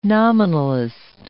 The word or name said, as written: nominalist